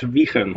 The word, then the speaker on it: Weichen: Yeah, you spell it Weichen, yeah. But you pronounce it as Wiegen.